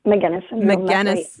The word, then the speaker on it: Magenis: McGinnis syndrome, and has gotten a lot of support from Heartland AEA.